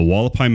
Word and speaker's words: Hualapai: And the Wallapai Mountains is spelled with an H. So it's like HUALAPAI or something. It's really weird spelling, but definitely check that out.